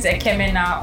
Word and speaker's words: Ekemena: Hi everyone, my name is Ekemena Olua Tobi Emeliereta.